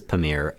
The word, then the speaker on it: Pamir: Of the Pamir, I guess it's Pamir, is how you pronounce it, mountain range.